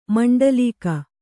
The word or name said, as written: ಮಂಡಲೀಕ